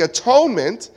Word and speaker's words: atonement: In fact, the Puritans used to call the atonement, they would pronounce the atonement as the at-one-ment. Because when atonement took place, you were at one with God.